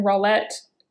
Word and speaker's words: Rawlette: You could definitely check out my website, Sharon Rawlette. And Rawlette is spelled R-A-W-L-E-T-T-E.